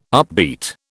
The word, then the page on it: upbeat: British English